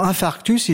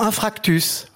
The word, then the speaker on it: infarctus: Hein, pour infarctus, ils diront infractus.